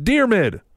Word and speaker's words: Diarmuid: He says, P.S., it's pronounced Dearmid. Silly nerds. Hashtag tweet the table. Yeah. Thanks, though. That's what I was asking for, is exactly how to pronounce it. So it's Dearmid. D-I-A-R-M-U-I-D. How could I not get that?